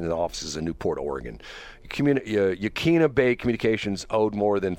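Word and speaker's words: Yaquina: Talks about five radio stations owned by Yakuna. I guess you'd pronounce it. It's Yakuina. Wakania Bay Communications. – Yeah, Yakuna Bay Communications. – Yakuna, okay.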